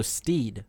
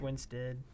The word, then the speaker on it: Winstead: S-T-E-D. – Stead. Like S-T-E-D? – S-T-E-A-D. – I knew that. – So Steed. – Winstead. – No, it's Winstead.